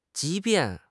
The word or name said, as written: 即便